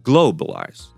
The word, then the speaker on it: globalize: Globalize, so we accentuate the first part, globalize, the first syllable.